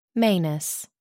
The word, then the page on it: manus: American English